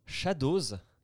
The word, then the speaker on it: Shadowz: Alors, allez sur Shadows ça s'écrit S-H-A-D-O-W-Z.